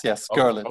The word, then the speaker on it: Gorlitz: Am I pronouncing it correctly, Conrad? – Yes, Gorlitz. – Okay, like Gorlitz. Yeah.